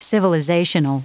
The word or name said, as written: civilizational